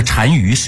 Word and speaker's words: 单于: 那单于是一个职位，这俩字是怎么写的呢？单就是单双的单，这是一个多音字，鱼是干钩鱼，这两个字在一起，在匈奴的职位叫做单于，就相当于汉人当时的大王或者是皇帝。